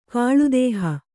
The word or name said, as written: ಕಾಳುದೇಹ